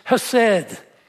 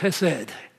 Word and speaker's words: chesed: Could never learn to speak it with the Hebrew roll in your throat, H. But as I said, a Hebrew professor, say it like you're clearing your throat. And he said, well, not like that. But it's chesed.